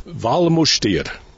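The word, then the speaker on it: Val Müstair: We are in a place which in English is called the Valley of the Minster Valley, or locally, and I'll try to get my pronunciation right, because here they speak Romansch, Switzerland's fourth national language, Wallmustier. Is that correct, Gabriella Binkert? – Yes, that's correct.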